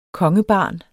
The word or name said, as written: kongebarn